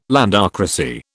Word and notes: landocracy: British English

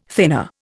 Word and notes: thinner: Indian

